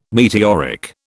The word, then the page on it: meteoric: British English